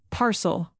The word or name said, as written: parcel